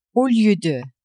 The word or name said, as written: au lieu de